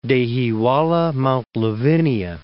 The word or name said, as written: Dehiwala-Mount Lavinia